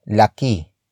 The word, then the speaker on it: laki: Malaking malaking is spelled as M-A-L-A-K-I-N-G hyphen M-A-L-A-K-I-N-G. It means very big. It comes from the root word laki.